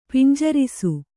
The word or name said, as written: ಪಿಂಜರಿಸು